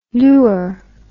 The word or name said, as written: lur